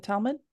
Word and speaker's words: Talmud: Torah and Talmud. Did I say that right? – That's me.